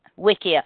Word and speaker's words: Wikia: Wikia is that how they pronounce yeah, it? Yeah, yeah. – Wikia.